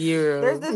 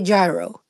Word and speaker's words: gyro: Oh, euro, Annie, euro. – We say gyro. You say euro.